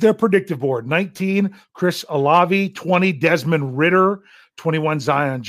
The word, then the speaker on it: Olave: You've got Chris Olave. I'm pretty sure I'm saying that right. People told me how I needed to pronounce it.